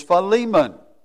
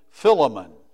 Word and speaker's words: Philemon: And the pronunciation of this book is Philemon. Now, Dale and I have had fun with that. He's messed with my mind. And I said, well, you know, you're in Southwest Virginia now. Some people pronounce it Philemon. I don't think that's right.